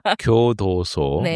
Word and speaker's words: 교도소: 그리고 최근에 그 교소도 발음이 안 돼. 교도소 네.